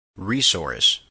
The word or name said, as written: resource